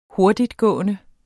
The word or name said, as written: hurtigtgående